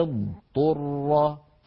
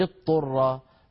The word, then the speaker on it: اضطر: لأنه يجتمع عند نطقها حرفان مفخمان مستعليان مطبقان، هما الضاد والطاء، والضاد قوية والطاء قوية، فعلينا أن نبين كل منهما ونخلصه من الآخر، هكذا فمن اضطر. فمن اضطو، اضطو الأول ضاد والثاني طاء، ولا نقول كما نسمع من بعض إخواننا فمن اضطر فمن اضطر صارت كأننا أدغمنا الضاد في الطاء ونطقنا طاء مشددة، وهذا لا يصح، هذا لا يصح.